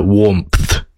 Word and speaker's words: Warmpth: Warmth is spelt W-A-R-M-P-T-H. So it's kind of, if you want to emphasize every letter, it's closer to Warmth.